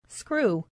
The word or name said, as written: screw